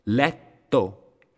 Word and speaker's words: letto: Letto, doppia T, letto.